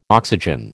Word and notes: oxygen: American English

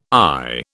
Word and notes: I: British English

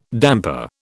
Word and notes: damper: British English

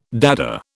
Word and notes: Dada: British English

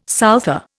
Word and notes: souther: Indian

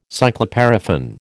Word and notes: cycloparaffin: American English

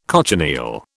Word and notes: cochineal: British English

